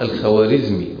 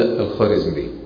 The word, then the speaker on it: الخوارزمي: الخارزمي تكتب بالواو، يعني بيسموها الخوارزمي، لكن عندما تقرأ لا تقرأ الخوارزمي، وهذا من الخطأ الكبير الذي يقع فيه كثير من الناس. يقول لك الدولة الخوارزمية، خوارزمشاه، هي خارزمشاه والدولة الخارزمية، تكتب ولكنها لا تنطق.